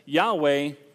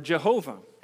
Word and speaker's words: Yahweh: For us today, with little training in Hebrew, we take the commonly accepted pronunciation as Yahweh or Jehovah.